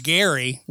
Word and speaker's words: Gerry: So I do live on Capitol Hill, and I don't know, I tweeted this out today, but Elbridge Gerry, weirdly, his name is pronounced with a hard G, is buried on Capitol Hill and Congressional Cemetery.